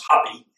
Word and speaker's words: Hapi: This particular God, they call Happy, probably pronounced Hapi, H-A-P-I. But that was the Egyptian god of the Nile.